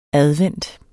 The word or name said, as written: advent